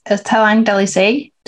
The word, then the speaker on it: Talang Dalisay: I think you started as Talang Dalisay.